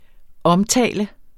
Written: omtale

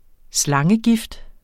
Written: slangegift

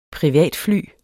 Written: privatfly

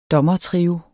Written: DOMMERTRIOEN

